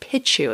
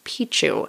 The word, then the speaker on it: Picchu: I also found out that I've been mispronouncing Machu Picchu. Lo, these many years. It's not Pichu. That's what I've been saying. It's Picchu.